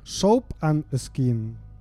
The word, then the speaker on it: Soap and Skin: Soap and Skin.